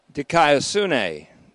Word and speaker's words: Dikaiosune: And there is an element of the Word of God, the general Word of God, called Logou, L-O-G-O-U, Dikaiosune, D-I-K-A-I-O-S-U-N-E, long E, Dikaiosune, Logou, Dikaiosune.